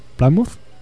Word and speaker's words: Plymouth: Non sei se o pronuncio ben, Xerardo. Plymouth? Plymouth? Plymouth? – Creo que está ben pronunciado.